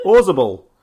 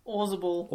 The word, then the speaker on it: Orzabal: So the two founding members, Roland Orzabal on guitars, keys, vocals. – Is that how you pronounce it? – Orzabal. – Orzabal.